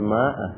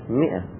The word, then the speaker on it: مئة: نحو الآن يمثل بالأمثلة: إذا كانت الهمزة مفتوحة وقبلها مفتوح سأل، مفتوح ومفتوح، إذا هي مفتوحة وقبلها مكسور مئة لا ماء، بعضهم يقول ماء، غلط، مئة تكتب ماء لكن تلفظ اللفظ الصحيح مئة، فمئة الهمزة مفتوحة وما قبلها مكسور، مفتوحة وما قبلها مضموم مؤجل، الميم مضمومة والهمزة مفتوحة.